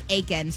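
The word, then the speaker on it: Aitken: It's pronounced Aiken? – It's pronounced Aiken. – So you don't even say the T.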